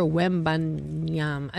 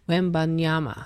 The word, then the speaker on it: Wembanyama: That's Victor Wembanyama. It says Wembanyama. You have to like make sure they do all syllables.